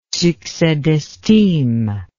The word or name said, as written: succes d'estime